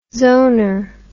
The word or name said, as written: zoner